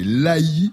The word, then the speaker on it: L'Haÿ: donc c'est l'Haÿ, qui vient d'un nom de domaine gallo-romain, l'Haÿacum, qui a évolué en lai, L-A-Y, puis en l'Haÿ, L-A-Y. Y avec un tréma sur le Y, l'Haÿ-les-Roses.